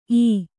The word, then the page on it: ಈ: ī